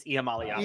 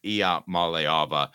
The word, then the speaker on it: Iamaleava: How did I do in the pronunciation there? – Not great. It's Iamaleava. I was just kidding. Iamaleava.